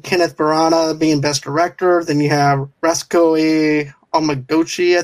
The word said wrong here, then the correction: Ryusuke Hamaguchi: I think that's how you say his name. – Ryusuke Hamaguchi for Drive My Car, yeah.